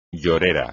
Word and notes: llorera: Spanish

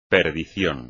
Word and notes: perdición: espagnol

